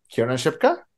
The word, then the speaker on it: Kiernan Shipka: Kiernan Shipka? – Thank you.